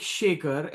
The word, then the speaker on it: Shekar: Yeah, it's like Shaker, S-H-E-K-A-R, like, but it's like, it's not, it's like salt and pepper Shaker, but it's S-H-E-K-A-R is how it's phonetically like written. And Natarajan, N-A-T as in Thomas, R as in Robert, A, Jack, J, A, N.